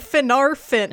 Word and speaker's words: Finarfin: No, but you even got the emphasis right, because the emphasis goes on the second to last syllable. So it's Fingolfin, not Fingolfin. It's Fingolfin and Finarfin.